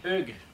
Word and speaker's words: Hugues: It's the sound U, you know? It's Hugues. – So what is the proper way to say your name? – Hugues.